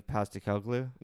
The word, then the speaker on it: Postecoglou: Pastikoglu? – It's like Ang Postecoglou.